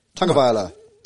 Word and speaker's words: Tagovailoa: How do you say it? Hang on, let's compare this. What did Tagovailoa. – You say? Tagovailoa, isn't it? Tagovailoa. – You're just cutting the A, that's all.